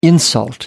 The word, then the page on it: insult: American English